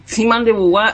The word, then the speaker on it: Simone de Beauvoir: Ahí está, ahí, ¿El está Simán de Beauvoir. Guap. Estás hablando de la canción. No, no, no. Simone de Beauvoir es una, una filósofa, una mujer. ¡Oh, no! Eh, eh, capaz que la pronuncié mal porque viste que yo.